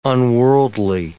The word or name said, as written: unworldly